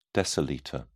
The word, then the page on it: decilitre: British English